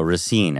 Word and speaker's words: Racine: I think you did, because I was saying Racine, and a nice young man in line corrected me. And everyone was like, yeah, idiot, newscaster guy. So Racine, I think, is how it's said.